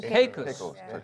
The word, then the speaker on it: Caicos: Here's a note from Jacqueline in the islands of Turks and Caicos. Is that right? Did I say that right? – Caicos. – Thank you. Turks and Caicos.